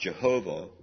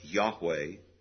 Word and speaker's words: Yahweh: So it's all in capital letters, L-O-R-D. Probably properly pronounced not Jehovah, but Yahweh.